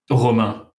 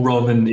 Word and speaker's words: Romain: Romain, or Roman, if you put it like in English.